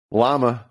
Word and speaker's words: llama: Llama, on the second L is silent. You only say all right.